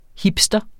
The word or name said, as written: hipster